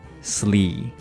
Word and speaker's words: seriously: Sear 和 rear 后面加上 slee，那注意了，这个 slee 也是一个长元音 e，而不是 slay。很多同学喜欢把副词后面这个 ly 读成 lay，错的，都是 lee。所以说这个单词的读法一定要慢一点，是 seer Seriously, seriously, seriously.